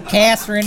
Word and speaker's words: Kasserine: Kasserine. – Jason knows history because he's a Michigan alum. – That's like what a Wisconsin mom says at dinner. – Kasserine.